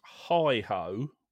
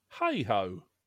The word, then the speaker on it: Heigh-Ho: which they definitely pronounce Hi Ho when they sing it. – Right. – But it has always been spelled H-E-I-G-H-H-O, which I think most of us, if we saw it written down, we would pronounce Hey Ho.